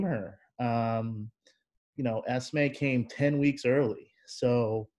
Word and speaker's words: Esme: Um, so her name's Esme, E S M E. Um, it's pronounced Esme, like like the letter S and the month May people say it all different ways, um, but that's you know that's that's her name, um, and her middle name's Beacon.